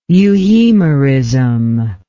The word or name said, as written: euhemeristically